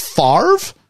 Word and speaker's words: Favre: Yeah, because it's pronounced Favre, but it's spelled F-A-V-R-E. – Favre. – Favre. So it looks like you should be split the V and the R around Favre.